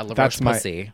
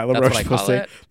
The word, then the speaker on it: La Roche-Posay: Oh yeah, La Roche-Posay. – Yeah, La Roche-Posay.